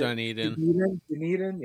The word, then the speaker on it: Dunedin: You know, they were still in what, Dunedin or whatever, however you pronounce it. – Dunedin. – Yeah. Never knew how to say that one.